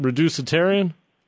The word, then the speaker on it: reducitarian: Is it reducitarian? – You said it beautifully.